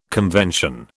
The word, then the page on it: convention: British English